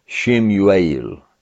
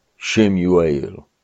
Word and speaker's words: Samuel: Now, I can't pronounce Hebrew words very well, but Samuel, just to give you an idea here, is something like Shemuel, Shemuel.